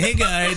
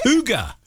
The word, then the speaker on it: hygge: He lets us go through a hygge in this. And he's just like laughing his head off, and he knows how you pronounce it. – What is it? – Hygge.